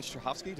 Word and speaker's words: Strahovski: Hey, Geekscapists, and I'm here with Yvonne Strahovski. Did I do it right? – Yes.